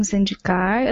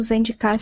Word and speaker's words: Zendikar: Ela vai parar em Zendikar. Eu não sei se eu tô falando Zendikar certo também, se é a pronúncia correta. – Pra mim é essa também.